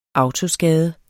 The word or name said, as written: AUTOSKADE